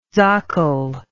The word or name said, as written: sakel